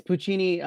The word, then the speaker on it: Puccini: Yeah, it's Puccini, La Boheme, I believe it was.